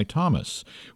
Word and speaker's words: Thomas: The one chosen thus will ascend to that coveted pinnacle of the dance world from which Beth has just been dethroned by Thomas, which is, by the way, pronounced Thomas.